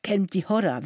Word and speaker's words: Kelmti Horra: ça se dit, oh je vais mal le prononcer, vous allez m'aider, Kelmti. – Kelmti Horra, version arabe.